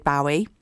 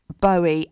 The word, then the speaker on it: Bowie: I always pronounced it David Bowie. And then I was told that he's got a daughter called Zoe. And his surname is Bowie, as in Zoe.